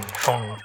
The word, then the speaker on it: Phaune: I mean, phone radio. It's spelled P-H-A-U-N-E. But it's pronounced phone. And it's radio.